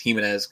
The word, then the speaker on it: Giménez: Ending on Giménez, I checked up the baseball reference. Giménez is pronunciation on him. So I don't know, taking baseball reference for it as much as I can. I think it's Giménez going forward.